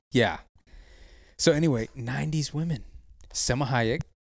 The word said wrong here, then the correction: Salma Hayek: Okay. Selma Hayek? – No, that's kayak. – So you tell me what it is. – I just did. – What? – Salma Hayek. – Salma Hayek. – There you go. – That's the real way to say it.